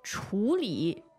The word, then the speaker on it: 处理: 因为这个字是多音字，第三声和第四声，但是处理要读第三声。第四声的话是用在这个名词上面，比如说到处、处处。